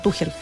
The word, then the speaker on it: Tuchel: Tuchel. está bien pronunciado, Tuchel.